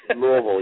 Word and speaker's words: Louisville: And this morning I am just thrilled to have as my special guest, who is based in Louisville. How do you say it? Louisville, Kentucky. – Louisville, yes.